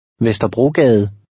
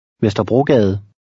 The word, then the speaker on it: Vesterbrogade: The final E is silent, and the G is hard. – Vesterbrogade. Gade. Vesterbrogade.